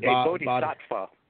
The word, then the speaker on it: bodhisattva: What is a bodhisattva. – Okay. – A bodhisattva, okay.